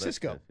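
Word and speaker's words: Sisqo: Man, I'm telling you, I didn't even know how to pronounce it until you said it. Because it's S-I-S-Q-O. I mean, how do you get that? – But Cisco.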